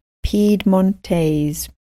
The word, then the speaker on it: Piedmontese: So Latin, Romanian, Catalan, Occitan, Galician, and Piedmontese.